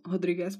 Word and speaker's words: Rodriguez: A na druhé straně Marina Rodriguez, nebo Rodriguez by se ho správně měla vyslovovat, má na kontě tři výhry v řadě a je tak trošku na koni, na cestě k možné titulové šanci.